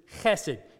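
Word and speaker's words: chesed (חסד): The word is chesed. You got to say it like there's something in the back of your throat. Chesed. Chesed. You can do it. Come on. Chesed. There you go. Chesed. You don't say chesed. It's not chesed.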